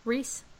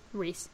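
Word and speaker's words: Rhys: Brown, This Is Us, Ed Harris, Westworld, Matthew Rise, the Reese, that's Reese. – Um, yes.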